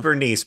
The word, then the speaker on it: Bernice: It was spelled Bernice, but it was spoken Bernice rhymes with furnace.